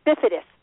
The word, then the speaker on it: bifidus: Is it I don't know if I'm pronouncing it. Bacivit. – The bifidus. Bifidus. – Thank you. Bifidus. Yeah.